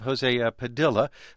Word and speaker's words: Jose Padilla: Then there's the case of Jose Padilla. Again, that's the right pronunciation.